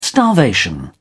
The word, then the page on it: starvation: British English